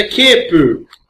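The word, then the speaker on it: kepu (ケープ): Em japonês é kepu.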